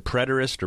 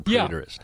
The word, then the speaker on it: preterist: There are people called, is it preterist or preterist? – Yeah, both. Both pronunciations are correct.